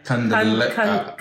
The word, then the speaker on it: Candelaria: Candelaria.